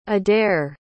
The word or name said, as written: adair